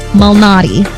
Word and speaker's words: Malnati: In golf, Peter Molinari. Wait, I got it. Molinari? No. – Illuminati? What? – That's not it. That's not it. And I was saying it right. It's just now I'm not saying it right. Peter Malnati. Malnati. – Leads, like the pizza place.